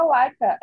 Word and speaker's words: Leica: Escreve Leica, mas fala Laica.